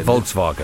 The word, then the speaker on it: Volkswagen: Yeah, how do I pronounce Volkswagen? Is it Volkswagen? – Volkswagen. – Volkswagen. – It is. – More like an F-ish.